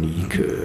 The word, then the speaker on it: Nike: Und das wird dann anders ausgesprochen. Nike, so griechisch.